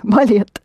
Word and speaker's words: балет: Pronounce it the way you spell it. – Ballet. Ballet. – Да, балет.